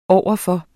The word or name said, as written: overfor